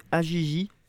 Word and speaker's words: AJJ: AJJ, comme ça se prononce, c'est littéralement trois lettres.